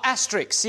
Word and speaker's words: asterisk: Asterisk. Risk, like the game Risk at the end. Asterisk, say it properly.